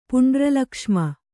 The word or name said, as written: ಪುಂಡ್ರಲಕ್ಷ್ಮ